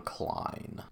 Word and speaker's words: Klein: This movie starts off two years earlier, and it follows, like I said, Washington Post columnist John Clean, who is played by Richard Gere. I think it's actually John Klein. Klein, Klein.